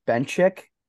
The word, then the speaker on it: Benchich: Well, I guess it's technically pronounced Benchich. Okay. That's what my dad always tells me, but I'm like, it's Croatian, so it should be Benchich, but like, yeah, we'll go with it's really Benchich.